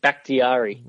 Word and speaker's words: Bakhtiari: David Bakhtiari.